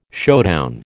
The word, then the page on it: showdown: American English